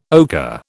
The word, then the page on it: ocker: British English